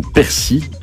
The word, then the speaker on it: persil: C'est le persil ou persil. – D'accord. – On ne prononce pas le L à la fin.